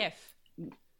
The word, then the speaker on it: GIF: It's graphical with a hard G. It's right. A GIF.